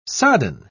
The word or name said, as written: sodden